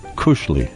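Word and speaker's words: koselig: What's the word? – Kushli. – Kushli. – That's kushli. – How it's pronounced in Norwegian, yeah.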